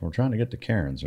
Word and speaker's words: Cairns: Plus, we were up in Darwin and up in the Northern Territory, and the reason that I ended up in I know you guys pronounce it Cairns, which it's spelled Cairns, but you know, I guess that's the accent difference.